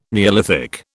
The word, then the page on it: neolithic: British English